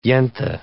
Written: yenta

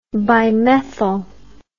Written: bimethyl

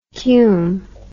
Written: cume

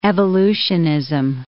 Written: evolutionism

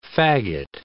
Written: faggot

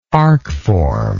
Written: arcform

